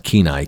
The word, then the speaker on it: Kenai: It's pronounced Kenai, K-E-N-A-I, Kenai, for the Kenaitse tribe, I imagine.